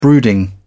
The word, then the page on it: brooding: British English